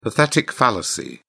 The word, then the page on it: pathetic fallacy: British English